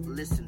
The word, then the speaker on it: LISAN: So I'm going to give you an acronym to help you listen better. And the acronym is LISAN, pronounced listen.